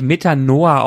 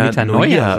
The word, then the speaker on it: Metanoia: Meta, Wird Meta, es wirklich Metanoa ausgesprochen? – Oder ich würde Metanoia, eher Metanoia sagen. Mit, Metanoia, genau.